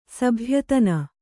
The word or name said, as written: ಸಭ್ಯತನ